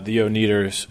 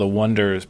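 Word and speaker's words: Oneders: Yeah, it's called The Wonders, but pronounce it The Oneaters.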